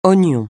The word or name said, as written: oignon